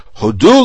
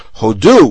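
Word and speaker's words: hodu (הודו): When we're saying Hallel and the leader invokes Hodu l'Adonai ki tov ki l'olam chasto, and says it properly, which is the word hodu with the accent on the second syllable, he's saying give thanks. If, on the other hand, he used to say hodu, which means India, it's the name of a country.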